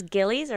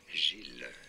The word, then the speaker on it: Gilles: Is this Gillies or Giles? – Giles? It's not Gillies, because there's no... – There's two L's, though. – Gilles, silent S there.